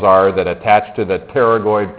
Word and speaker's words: pterygoid: And they are called the pterygoid. The P is silent, just like in pneumonia, right? So these are the pterygoid processes.